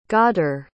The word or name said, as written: ghadir